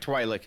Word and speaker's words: Twi'lek: Twi'lek, yes. – I don't know how to pronounce it. Yeah, so. – I just looked it up. – No, that sounds right, Twi'lek.